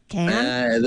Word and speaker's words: Cannes: In Cannes. And that's how it's pronounced, correct? Cannes? – That's what I'm told.